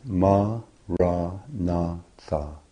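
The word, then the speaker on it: maranatha: Say it as four syllables of equal length, ma, ra, na, tha.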